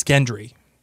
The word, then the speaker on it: Gendry: I think that Tyrion and Sam are needed to figure out how to make Valyrian steal with Gendry. – Yes, I would agree with you on that one. – Do I say his name wrong every time? – I think it's Gendry.